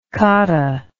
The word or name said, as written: cotta